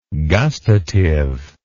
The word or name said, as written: gustative